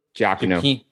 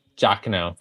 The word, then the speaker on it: Giacchino: I mean, Michael Giacchino. – Giacchino, thank you.